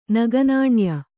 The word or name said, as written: ನಗನಾಣ್ಯ